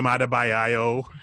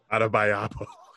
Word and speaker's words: Adebayo: With Jimmy Butler, Bam Adebayo. However you say his name. – God, you butchered that. – Adebayapo. And you had to stay adding extra syllables.